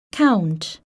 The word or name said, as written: count